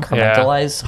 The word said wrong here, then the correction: compartmentalized: Compartmentalized? – Thank you.